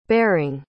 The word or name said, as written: baring